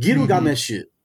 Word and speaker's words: Gilgamesh: It kind of like has sing-songy because the Japanese pronounce it Giru Gameshu. Giru Gameshu.